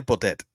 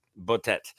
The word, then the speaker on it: Botet: Javier Botet. – Botet.